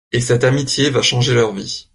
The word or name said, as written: vies